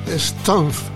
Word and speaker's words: Stumpf: Es la historia de Andy Stumpf. Se escribe S-T-U-M-P-F, tal como suena.